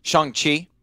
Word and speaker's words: Shang-Chi: I will say I saw Shang-Chi about a week ago or 2 weeks ago, proper pronunciation. Good job. – Thank you. See, I didn't say Shang. I said Shang-Chi.